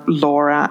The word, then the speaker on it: Lora: So I can be found at lauraobryan.ie, and my first name is spelled L-O-R-A. So really, if you Google L-O-R-A O'Brien or Laura and like witchcraft or Laura and weird Irish pagan or anything like that, you're going to find me.